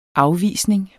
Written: afvisning

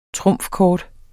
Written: trumfkort

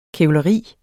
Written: kævleri

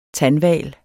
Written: TANDHVALEN

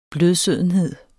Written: blødsødenhed

